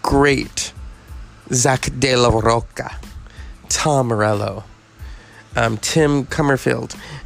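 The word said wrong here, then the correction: Commerford: And, or did I say that right? Sorry. I always mess that up. We're going to do this properly. Brad Wilk. Tim Comerford. – Comerford. – Okay, thank you.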